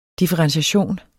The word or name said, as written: differentiation